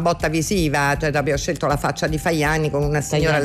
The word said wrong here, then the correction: Tajani: Tajani. – Leggermente. – Tajani, si è detto Fajani, va bene.